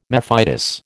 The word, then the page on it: mephitis: American English